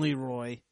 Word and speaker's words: Leroy: It's Leroy. L-E-R-O-Y, Leroy. That's how you would pronounce it, right? – Leroy Jenkins!